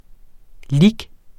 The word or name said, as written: lic.